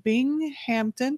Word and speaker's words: Binghamton: Suzanne earned her bachelor's degree from Binghamton. Did I say that correctly? – That is perfect.